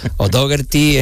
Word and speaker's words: O'Dougherty: O'Dougherty es la manera más aproximada o castellanizada de como se dice en inglés, en irlandés, sí, ¿no?